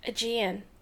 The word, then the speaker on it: Aegean: Aegean.